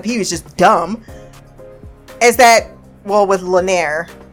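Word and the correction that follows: Laenor: Lanair? Now you got me fucked up on the name. Lanor. Lanor is how you pronounce it.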